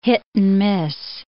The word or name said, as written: hit-and-miss